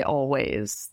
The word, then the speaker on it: Alvvays: I'd say music-wise, I'm really being inspired by Always, that band, Alvvays. That's how they spell it.